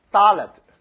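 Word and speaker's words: Dalet (דלת): Dalet，搭低的音，是像英文低的音 Dalet。好，我们大声来读 Dalet。它的写法是这样下来打个点，所以为什么主耶稣说一点一画都不能废去，少了一个点就是不同的字啊，每一个点都很重要啊。 Dalet 是低的发音，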